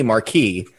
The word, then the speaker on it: Marquis: who I believe his name is like Marquez. – Yeah. – Marquel. – I think it's supposed to be Marquis. – Marquis, they keep mispronouncing it.